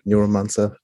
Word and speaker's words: Neuromancer: war Gibson mit dem Buch Neuromancer oder Neuromancer, dann englisch ausgesprochen, ähm, wo auch eine KI unterwegs ist, ja.